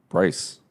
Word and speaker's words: Bryce: My name is Bryce. Like one syllable, just there it is.